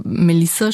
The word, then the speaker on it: Melisa: Genau, also Melissa schreibt man bei mir mit einem S, weil im Bosnisch-Serbisch-Kroatischen gibt es keine Doppelkonsonanten. Jetzt haben das alle immer Melisa ausgesprochen und als kleines Kind, auch als Jugendliche traut man sich nicht, einer Autoritätsperson zu erklären, dass das falsch ist.